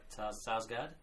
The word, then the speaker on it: Sarsgaard: No, I did pronounce it right, Sarsgaard. – It is Sarsgaard.